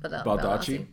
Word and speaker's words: Baldacci: Baldacci. – Baldacci, mm-hmm.